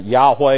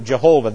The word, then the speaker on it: Jehovah: And it's, you can pronounce it Yahweh if you want, but it's actually in English proper to pronounce it Jehovah.